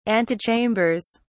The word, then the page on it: antechambers: British English